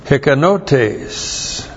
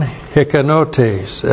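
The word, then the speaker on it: hikanotes (ἱκανότης): And the adjective is hikanos, H-I-K-A-N-O-S. And there is a noun that comes from that verb as well, hikanotes. H-I-K-A-N-O-T. Long E-S. So here's the verb, hikanao, that's in verse 6, and from this verb comes an adjective, hikanos, and a noun, hikanotes,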